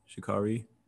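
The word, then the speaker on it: Sha'Carri: One thing that caught my eye was Shikari, right? Did I say that right? Shikari.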